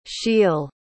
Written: scheele

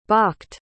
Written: bakht

